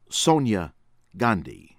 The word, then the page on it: Sonia Gandhi: American English; from India